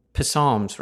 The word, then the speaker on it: Psalms: Psalms, right? The P is silent.